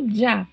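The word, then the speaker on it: ya: E a pronúncia desta Y junto com a letra A é uma pronúncia semelhante a um J. É como se fosse um D e um J juntos. Já.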